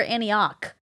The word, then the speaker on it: Antioch: Is it Antioch or Antioch? – Antioch is how they say it.